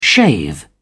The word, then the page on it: shave: British English